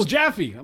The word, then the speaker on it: Jaffe: And that's from Arnold Jeffrey in St. Louis. – You misspelled Mr. Jaffe's name. You mispronounced. – Oh, Jaffe. – Jaffe. – My bad. Arnold. – It's Arnold Jaffe.